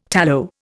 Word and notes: tallow: Indian

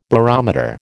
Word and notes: barometer: American English

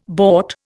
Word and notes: bought: Indian

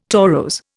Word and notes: torose: Indian

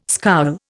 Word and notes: scowl: Indian

